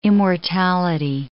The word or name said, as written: immortality